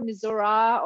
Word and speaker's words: Missouri: How do you actually pronounce it? What's the correct way? Is it Missouri, Missouri, or Missouri, or what is it? it, par- it, depends on what part of the state you're coming from. Um, I always call it Missouri.